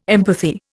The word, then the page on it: empathy: Indian